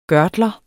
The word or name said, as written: gørtler